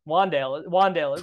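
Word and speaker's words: Wan'Dale: One of them's going to get 150 and two and we're going to be like, It's Wandale. Wandale is the one who's going to do it. – Oh God. Rhymes with Rondale, which rhymes with low A dot.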